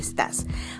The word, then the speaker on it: estás: And you have to pronounce the S at the end, ¿cómo estás?